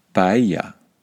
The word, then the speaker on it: paella: If we try to pronounce these French words like the French, then we should try to pronounce the Spanish words that are in the English language like the Spanish. So we should say paella. We should say chorizo.